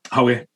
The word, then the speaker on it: Huawei: They're best pronounced in a Geordie accent. Where would you say, howie? – Howie.